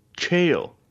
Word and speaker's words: Chayil: and then we've been saying uh, we've been saying Chayil Garnett. That is not the correct way to say it. Yeah, Chayil. Interesting. One syllable.